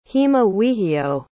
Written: heammawihio

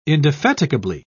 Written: indefatigably

